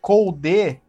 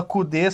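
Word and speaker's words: Coudet: o time do Inter com Eduardo Colde, que é assim que pronuncia, segundo os jornalistas argentinos do Twitter, porque você fala Cude só porque é pra comer o cu de quem tá lendo.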